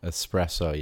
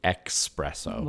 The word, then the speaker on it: espresso: Espresso, yeah, that's probably it. Or the expresso. – Well, that's right. So that's how it's normally mispronounced with the X. Expresso. – Yeah. It's espresso.